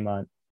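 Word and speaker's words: Mundt: So some Vikings fans have started calling him Munt Cake, which I think is amazing. It's incredible. And his name is spelled like Bunt Cake. It's UNDT, right?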